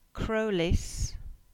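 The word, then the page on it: Crowlas: Cornish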